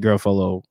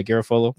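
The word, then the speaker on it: Garafolo: If you look at it, it's really just Mike Garofalo, or however you say his name. – NFL you said it right. – Yeah, Garofalo. – There you go.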